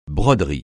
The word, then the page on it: broderie: francais